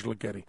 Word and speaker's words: Ligeti: I think I'm saying his name right. That's the pronunciation the Times gave. George Ligeti.